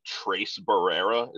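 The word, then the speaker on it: Tres Barrera: Is it Trace Barrera? Is that how you pronounce it? – Yeah, the guy on the Nationals.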